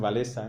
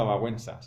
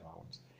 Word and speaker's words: Wałęsa: Ahora vamos a hablar del eje Wałęsa, aunque todos le llamen Walesa, en realidad eso en polaco se pronunciaba Wałęsa, se pronuncia Wałęsa.